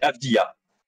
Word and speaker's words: Avdija: C'est Avdija.